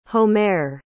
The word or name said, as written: homare